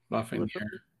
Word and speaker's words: Lafreniere: How do you say his name? – Lafreniere.